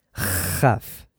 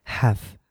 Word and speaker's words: have: You pronounce have. You never say have, you know, like Arabic people, como en árabe. No. You say have. It's very, very soft. Es una H aspirada, muy, muy suave.